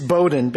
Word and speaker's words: Bowdoin: Yes, it's Bowdoin because I was constantly reminded from day one when I went to college that people on the East Coast knew how to pronounce that college.